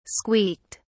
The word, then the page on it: squeaked: British English